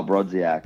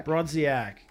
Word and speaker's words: Brodziak: Oh, Kyle Brodziak. – Brodziak.